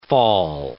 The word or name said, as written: fall